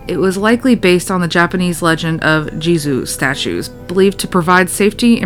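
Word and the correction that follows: Jizō: In Japan, oh, it's pronounced Jizo. I'm stupid.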